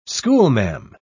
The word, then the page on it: schoolma'am: British English